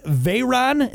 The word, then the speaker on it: Veyron: The Bugatti Veyron, did I say that right? – Yes, you did.